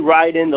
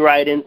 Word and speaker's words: Riden: And I'm on Facebook, Jeremy Ryden. The way you spell that last name is R-I-D-E-N, Jeremy Ryden.